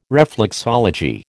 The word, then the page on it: reflexology: American English